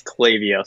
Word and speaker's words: Klaviyo: But Klaviyo, we had to make phonetic pronunciation t-shirts to help people know it's Klaviyo.